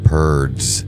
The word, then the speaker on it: Purds: Ryan Purds. – I'm sorry, I just think his last name is fun. – Rhymes with turds.